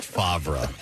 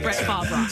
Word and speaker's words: Favre: By the way, Kathy, it's pronounced Favre. – I saw a movie one time. – Favre.